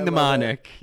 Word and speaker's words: mnemonic: Know me, monomic. – Know me, monomic. – Know me, mnemonic. Yeah, know me, mnemonic.